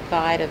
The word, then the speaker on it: Vida: Now, Mr. Seay, in answer to your question, when I was born, I was given the name of Vida, V-I-D-A, which is my mother's name.